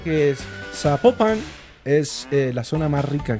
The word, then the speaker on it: Zapopan: Oiga, nos escriben, nos escriben los oyentes y que no se, no se pronuncia Zapopan, sino que se pronuncia Zapopan. – Ah, Zapopan.